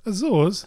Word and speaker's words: Azores: A-Z-O-R-E-S. Azores. – Azores? – Azores.